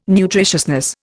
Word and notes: nutritiousness: Indian

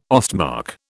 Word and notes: Ostmark: British English

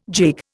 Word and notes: gigue: Indian

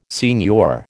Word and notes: signor: American English

